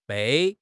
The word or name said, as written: bei4